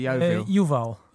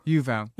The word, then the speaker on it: Yuval: I'm saying your name correctly, Yuval. – Yuval. Yeah, like it's written. There is no secret there.